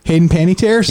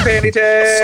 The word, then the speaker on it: Panettiere: Hayden Panettiere? – Yes. – Hayden Panettiere.